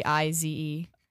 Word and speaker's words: Heize: She's on a lot of his songs, and we'll talk about it's Heize. It's like Haze, but a little bit of emphasis on the E.